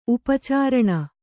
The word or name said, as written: ಉಪಚರಣೆ